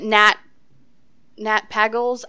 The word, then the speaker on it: Pagel: Like for like for example, the Nat Pagels. I don't know how to say his name. – Nat Pagel, yeah. – Pagel, thank you.